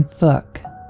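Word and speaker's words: Fouke: The film uses the pronunciation Fook, which sounds like book.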